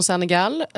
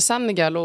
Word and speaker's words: Senegal: Men det här med Senegal, och det har ju varit där några gånger. Och, är det Senegal? – Senegal. – Inte Senegal? – Jag betoningen, säger Senegal. – Betoningen ligger på första stavelsen.